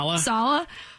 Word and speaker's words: Saleh: Because it's hard to predict if whether or not, you know, is it Saleh? How do you say this last name? – Saleh.